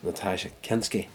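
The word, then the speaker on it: Nastassja Kinski: Denham Elliott plays her father, and the young nun, of course, is Natasha Kinski.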